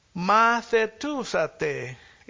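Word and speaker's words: mathetousate (μαθητεύσατε): Mathetousate is the Greek. And in Greek, the eta or the eta is pronounced as e. So it's mathetousate.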